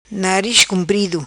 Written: Nariz Cumprido

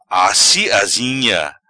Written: Assi Azinha

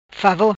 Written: favor

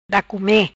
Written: Da come

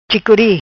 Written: Chicori

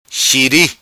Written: Xiri

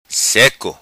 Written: Seco

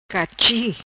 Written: Cachi

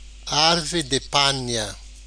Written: Arvre Di Panha

